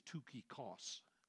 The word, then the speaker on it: Tychicus: I know that different people pronounce that different ways. In the Greek, it doesn't even sound like that. In fact, in the Greek, it's Tychikos. Tychikos. That just sounds dumb enough that I'm not going to say that to you this morning. So if you want to pronounce it Tychicus, that's fine. Or Tychicus, that's fine. Or Tychikos, that's fine. Because all of them are incorrect equally.